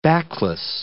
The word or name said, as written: backless